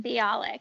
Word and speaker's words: Bialik: Bialik, yes.